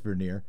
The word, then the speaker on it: Vernier: David Vernier, it's just Vernier. You don't have to give a French French pronunciation.